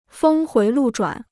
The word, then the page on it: 峰回路转: fēng huí lù zhuǎn